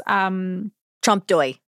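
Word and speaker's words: trompe-l'œil: And I was reading that Kon wanted to make, and if I pronounce this wrong, I apologize to all French people, but the artistic style Trompe L'Oeil, which is Trompe d'oeil. – Well, there you go. I won't try again.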